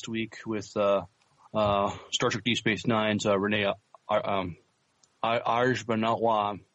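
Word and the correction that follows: Auberjonois: I'm butchering his last name. – Aubergenois. – Thank you.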